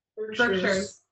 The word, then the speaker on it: Berkshires: That's how you say it, Berkshires? – Berkshires. – Oh, I just thought it was Berkshires.